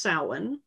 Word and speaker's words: Samhain: It's spelled Samhain, but it's pronounced Samhain.